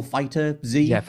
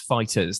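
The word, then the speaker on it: FighterZ: I love Dragon Ball Z or Dragon Ball Fighter Z. – Fighters. Also, I mean, we've been corrected on that many times. It's pronounced Fighters. That's how we got to pronounce it, apparently.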